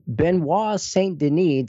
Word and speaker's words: Benoit Saint Denis: Benoit Saint Denis, did I say that right? – Sounds right to me.